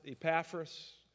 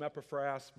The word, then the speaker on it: Epaphras: His name is Epaphras. Some people might pronounce it Epaphras. We don't really know. I call him Epaphras,